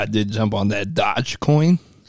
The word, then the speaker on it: Doge: Yeah, but it's Doge because it's D-O-G-E. – I know. That's why I said the E's silent. – Shh. – The E is silent.